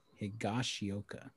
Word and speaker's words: Higashioka: Higashioka.